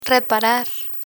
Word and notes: reparar: Spanish

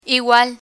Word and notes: igual: Spanish

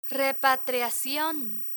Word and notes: repatriacion: spanish

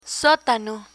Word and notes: sotano: Spanish

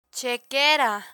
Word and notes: chequera: spanish

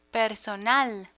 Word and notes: personal: Spanish

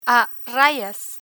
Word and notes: a rayas: Spanish

